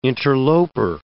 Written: interloper

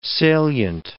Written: salient

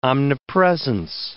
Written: omnipresence

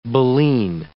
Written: baleen

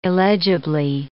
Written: illegibly

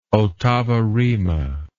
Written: ottava rima

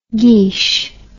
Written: geech

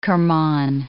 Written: Kirman

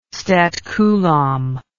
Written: statcoulomb

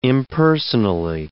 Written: impersonally

